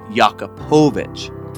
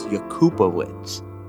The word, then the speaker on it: Jakubowicz: my instinct is to say Jakubovic, to put the accent on the po. – On the po. It's not. – It's not. – It's Jakubovic.